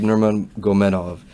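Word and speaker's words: Nurmagomedov: How do you say his name? But um, Nurma Nurma Gomedov, Nurma, I was somewhat close. Nurma Nurma Nurma Gomedov, yes, Khabib Nurma Gomedov.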